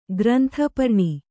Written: ಗ್ರಂಥಪರ್ಣಿ

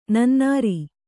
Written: ನನ್ನೂರು

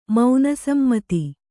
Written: ಮೌನಸಮ್ಮತಿ

